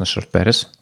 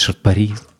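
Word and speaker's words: Short Paris: Это, конечно, Шорт Пэрис. У меня есть странное отношение к группе Шорт Пэрис. Пэрис, Парис, Шорт Парис как угодно, короче. Насколько я знаю, они произносятся вообще как угодно.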